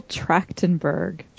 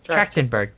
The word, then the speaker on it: Trachtenberg: Michelle Trachtenberg. – Trachtenberg, there you go.